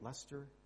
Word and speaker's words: Leicester: Is that how you say it, Leicester? Yeah. You know, we don't have to make that decision and go back and forth in our minds because we've already seen the tape.